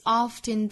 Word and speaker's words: oft: 后面一句 The good is often turned. OFT 这个词到美音之中呢，同样也是我们取 often 这个词的发音的前半部分。